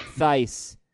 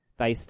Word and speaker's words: Thaiss: His name, his last name is spelled T H A I S S, but Thice, Thice, Thice, baby, Vanilla Thice.